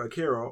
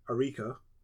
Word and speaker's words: Arika: which I'm quite sure we all pronounced incorrectly for years, right? – Because I was incorrectly calling them either Akira or Araki, their Arika, released a...